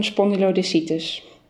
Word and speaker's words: spondylodiscitis: Maar in de casus die wij hebben beschreven op een spondylodiscitis. – Ja. Ja, goed uitgesproken zeg. – Nou, geen idee. Ik hoop dat het zo moet. – Ja, wat is het? Een spondylodiscitis.